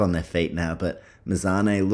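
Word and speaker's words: Mazzoni: Just quickly, 2 minutes left in this first round, and I think it's pronounced Mazzone, so I apologize if I've been getting her name wrong.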